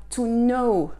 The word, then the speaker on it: know: To know. Hein, to know. Ne prononcez pas le K.